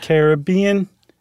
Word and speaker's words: Caribbean: It's the Caribbean. Yeah, you have to say it like you're about to sing the Billy Ocean song.